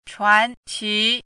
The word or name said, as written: chuanqi